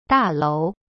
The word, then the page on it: 大楼: da-lou